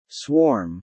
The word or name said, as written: swarm